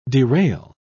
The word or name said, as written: derail